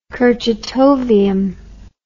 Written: kurchatovium

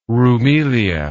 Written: roumelia

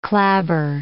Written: clabber

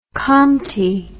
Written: Khamti

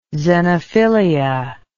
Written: xenophilia

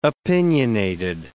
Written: opinionated

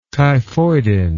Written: typhoidin

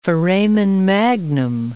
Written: foramen magnum

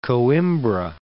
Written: Coimbra

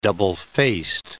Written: double-faced

